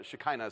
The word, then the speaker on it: Shekinah: This is where we get the word Shekinah or uh, Shekinah, is how it's pronounced.